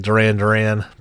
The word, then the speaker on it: Duran: I assumed it wasn't pronounced like Duran, but it is. It's Duran.